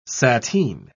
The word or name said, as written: sateen